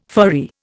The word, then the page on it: furry: Indian